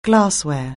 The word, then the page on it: glassware: anglais